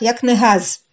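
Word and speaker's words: יקנהז: Аббревиатура этих благословений произносится как «якнегаз» по их названиям «яин», «Кидуш», «Нер», «Авдала», «Зман», «Якнегаз».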